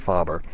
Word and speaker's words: Faber: or Faber, as people, I think it's most frequently pronounced Faber.